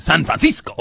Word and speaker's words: San Francisco: He might go out to San Francisco. How do you say that? The place where the 49ers play. – San Francisco? – Is that wrong or right? – No, that's right.